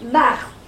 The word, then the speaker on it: Marthe: My name is pronounced Mart. M-A-R-T, the rest is silent.